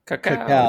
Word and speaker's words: cacao: And I know you don't like me saying cow, but now that you pointed out that I didn't say it right, is it cow or cacao? – It's cacao.